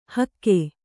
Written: ಹಕ್ಕೆ

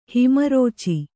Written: ಹಿಮರುಚಿ

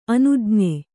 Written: ಅನುಜ್ಞೆ

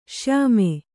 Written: ಶ್ಯಾಮ

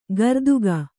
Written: ಗರ್ದುಗ

